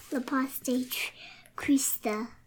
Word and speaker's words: La paz de Cristo: You say La paz de Cristo. – La paz de Cristo. – Cristo. – That's how I say that. – That's how you say it.